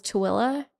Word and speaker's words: Tooele: It's spelled T-O-O-E-L-E. And it's just like, there's no way for you to get from that word to that pronunciation, like at all. Like, it's just not even possible.